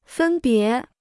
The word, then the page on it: 分别: fēn bié